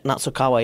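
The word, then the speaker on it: Nasukawa: How do you say his last name? Natsukawa. – Natsukawa, yeah.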